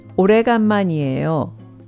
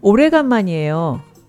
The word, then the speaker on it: 오래간만이에요: 오래간만이에요. 오래간만이에요. 오래간만이에요.